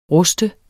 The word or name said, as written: rust